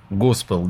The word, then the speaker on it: госпел: Возникает желание сразу задать вопрос о таком направлении творчества вашего, да, как госпел, да, это правильное ударение? – Правильно, абсолютно.